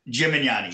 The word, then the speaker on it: Gemignani: Gemignani. Gemignani.